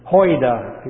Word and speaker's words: oida: The word "know" there is not gnosko, but oida. Hoida. It has a little H on the front of it in pronunciation.